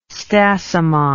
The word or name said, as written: stasimon